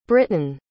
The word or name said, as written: brittan